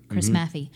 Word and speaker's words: Chris Maffei: Well, every time I edit the podcast and I put in our intro, that was done by the wonderful Chris Maffey, right? I'm saying it right? Yes, I think that's right. Chris Maffey.